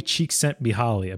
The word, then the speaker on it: Csikszentmihalyi: What people really want is not an easy life, but a fulfilling life, according to research by Mihaly Csikszentmihalyi.